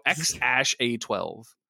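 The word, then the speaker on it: X Æ A-12: When Rogan asked how to say it, to which Musk responded, I mean, it's just X, the letter X, and then AE is like pronounced ash, and then A12 was my contribution. So X ash A12.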